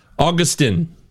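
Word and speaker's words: Agustin: So he spells his name wrong. A-G-U-S. – Yeah. Skip the first U. Augustine. Augustine. No, it's not Steen because there's no E, so it's Augustine.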